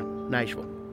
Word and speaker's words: Nashville: Supposed to be two syllables. This is Nashville. You can run it together. Nashville.